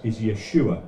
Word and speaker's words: Yeshua: Jesus' name in Hebrew is Yeshua. That's how you'd say it.